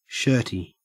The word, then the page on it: shirty: Australian English